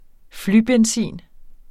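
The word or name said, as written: flybenzin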